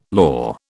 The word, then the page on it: law: British English